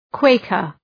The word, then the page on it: Quaker: ‘kweıkər